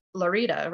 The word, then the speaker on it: Lorita: And tell us a little bit about Lorita, which is actually spelled like Florida without the F, but it's pronounced Lorita, right? Like a drink. It sounds like a cocktail.